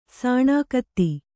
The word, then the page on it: ಸಾಣೆಗತ್ತಿ: sāṇegatti